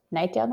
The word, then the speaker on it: Knightdale: Knightdale, North Carolina, just like it's spelled.